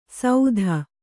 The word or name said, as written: ಸೌಧ